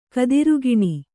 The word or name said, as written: ಕದಿರುಗಿಣಿ